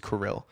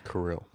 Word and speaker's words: Kirill: And uh, I said his name wrong last time I mentioned him on the podcast. His name mm-hmm. is Kirill. Kirill. Yeah, K I R I L L.